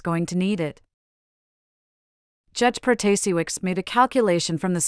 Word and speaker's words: Protasiewicz: In an interview at her home on Tuesday before the results were known, Judge Protasiewicz, pronounced Protasiewicz, attributed